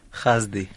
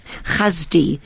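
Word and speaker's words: Hasdi: Khazdi. Khazdi.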